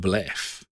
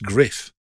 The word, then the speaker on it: Gruff: Because I didn't know that GRUFF is pronounced griff. – You've been saying gruff all these years, which would describe your voice in the last few weeks.